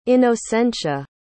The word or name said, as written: inocencia